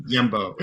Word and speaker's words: Hjembo: your surname is is spelled H J E M B O. You know, H J like yeah, yeah. yeah. Yembo.